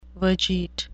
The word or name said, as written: vegete